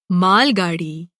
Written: गाड़ी

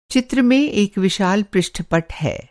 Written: पृष्ठपट

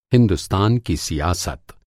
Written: सियासत

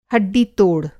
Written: हड्डी-तोड़

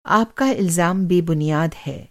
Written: इलज़ाम

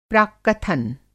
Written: प्राक्कथन

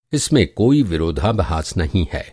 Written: विरोधाभास

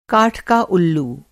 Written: उल्लू

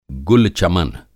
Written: गुल-चमन

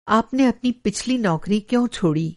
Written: छोड़ना

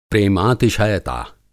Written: प्रेमातिशयता

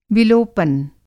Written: विलोपन

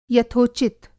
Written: यथोचित

